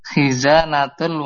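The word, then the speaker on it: Khizanatul (خزانة): Khazanatul waladi. Artinya lupa, Ustaz. – Apa ini bacanya betul? Nomor 3. – Khizanatul waladi. – Naam, khizanatul waladi.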